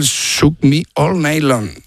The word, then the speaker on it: Shook Me All Night Long: Shoot Me All Night Long. – Ah, sí. Lo has dicho en francés. – Has cambiado cuatro palabras de sitio. – Has engolado tanto que se te ha pasado de idioma. – Qué grande, tío. Qué grande. – Me cuesta el Shook Me All Night Long. – Ahí muy, mucho mejor.